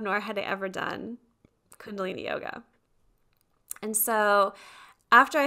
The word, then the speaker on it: Kundalini: Okay, Kundalini yoga.